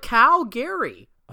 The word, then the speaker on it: Calgary: I just got back from Calgary, which is how the locals say it, which made me feel really, really crazy. – Would they actually do? – Yes. They call it Calgary.